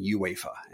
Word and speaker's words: UEFA: I was making the U silent, sort of. It was kind of like in, I guess in Spanish, maybe you would pronounce it UEFA if it was spelled like that. But I have now learned, and I am saying UEFA, and that was actually a chapter title, so.